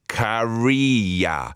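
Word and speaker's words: Käärijä: This is last year's Finnish Euro entry, which I said Karija, I think, which is terrible. It's Karija. No umlauts with the two dots is one of the additional letters in the Finnish language, along with the A with a little circle above it and the O with two dots, pronounced as air.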